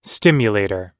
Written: stimulator

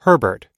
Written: herbert

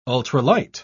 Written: ultralight

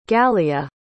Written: gallia

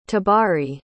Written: tabari

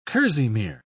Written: kerseymere

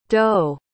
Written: d'oh

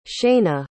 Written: shayna

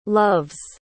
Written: loves

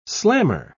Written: slammer

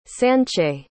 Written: sanchay

